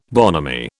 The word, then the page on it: bonhomie: British English